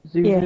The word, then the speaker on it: Zozo: It's like it's spelled Zozo, but is it pronounced Zuzu? – Zuzu.